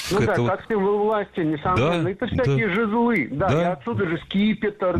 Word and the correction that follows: жезлы: Жезлы. Неверно. Правильно, жезлы. Ударение в этом слове ставим всегда на первый слог.